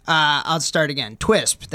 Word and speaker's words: Twisp: Oh, Twisp. so Twisp, that is not a mispronunciation. – That's T W I S P, not T.